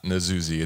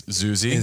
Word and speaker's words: Nzuzi: And he has a silent N at the front of his name too, right? – Yes. – It's not Nzuzi, it's Zuzi.